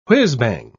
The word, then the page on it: whizzbang: British English